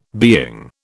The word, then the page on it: being: British English